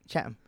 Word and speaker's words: Chatham: Chatham, yeah.